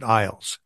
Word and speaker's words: Ilse: But when they came to the U.S., they pronounced it Iles, I-L-S-E. But if you say something, Iles, to somebody, and I spent a lot of my life spelling my name for people.